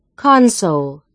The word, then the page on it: console: American English